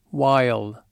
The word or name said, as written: while